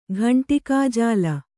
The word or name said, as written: ಘಂಟಿಕಾಜಾಲ